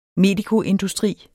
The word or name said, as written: medikoindustri